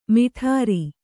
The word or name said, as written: ಮೇಢ್ರ